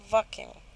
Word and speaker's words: Wacken: Y hablando, por cierto, de festivales, podemos hablar un poquito del Walking Open Air. Bueno, en alemán se dice Wacken.